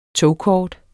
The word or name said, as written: togkort